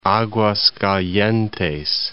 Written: Aguascalientes

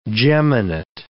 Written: geminate

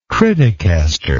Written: criticaster

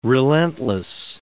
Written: relentless

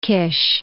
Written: Kish

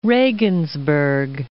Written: Regensburg

